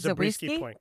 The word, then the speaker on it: Zabriskie Point: How do you say it? – Zabriskie Point, correct.